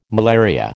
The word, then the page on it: malaria: American English